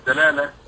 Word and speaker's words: دلالة: وكلمة دلالة إما يعني هي كلمة مثلثة يمكن أن تكسر الدلالة أو تفتح الدلالة أو تضم الدلالة، كله سواء، يعني من ناحية اللغة ما في إشكال في كون هذه الكلمة مثلثة تنطق بالأوجه الثلاثة.